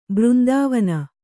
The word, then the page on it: ಬೃಂದಾವನ: břndāvana